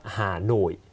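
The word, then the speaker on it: Hà Nội: ฮาโนยฮานอย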